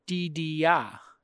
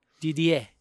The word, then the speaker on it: Didier: Spelled D-I-D-I-E-R. Didier. – Mm-hmm. – But the original pronunciation, Didier. – Didier. – Didier. That's the French. – That's the French. – But Australian, Didier? – Mm-hmm.